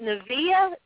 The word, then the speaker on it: Nevaeh: Uh, however, on the way back to the um, ring, is it Nevaeh? That's how you say her name. I just want to make sure I'm pronouncing it right. – I believe that's and right.